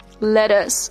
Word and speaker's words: lettuce: Next one, lettuce, lettuce. Okay, double T pronounced like D in American accent, and the letter U pronounced like uh.